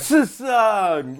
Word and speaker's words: Tsung: you know that T got pronounced yeah. hard. – Tsung, yeah.